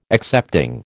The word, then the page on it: excepting: American English